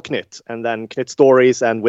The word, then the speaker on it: Knut: I mean, Knut, which, by the way, is how you pronounce it. Knut, it's a Swedish word meaning basically a tiny creature.